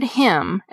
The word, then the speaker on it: hymn: The word hymn, as in a hymn that you sing at church, has a silent N at the end of it.